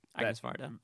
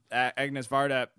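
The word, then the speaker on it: Agnès Varda: I'm pronouncing Agnes Varda. – Agnes Varda, but One Girl Sings, One Does Not, or One Sings, One Does Not was a really big fun surprise for me.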